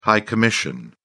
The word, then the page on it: high commission: British English